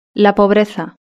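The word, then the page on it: la pobreza: Spanish (Spain)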